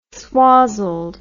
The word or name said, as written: swazzled